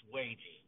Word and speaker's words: Watje: So Matt, I've been called a lot of things, and you could sure enough called me worse things than Watchy, but it's wagey.